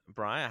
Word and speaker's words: Bry: Hello, my name is Bry. I have a Twitch channel called Bry Supersaurus. That's B-R-Y, and then of course the extremely well-known Supersaurus.